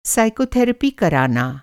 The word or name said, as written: साइकोथेरेपी